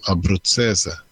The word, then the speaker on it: abruzzese: Jag vet inte, eh, om det är italienskt då ska egentligen dubbel-Z uttalas TZ, så alltså abruzzese.